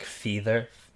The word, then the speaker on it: Fether: And it's like, it's spelled Feather without the A. So it's like Feather, F-E-T-H-E-R. Anyways.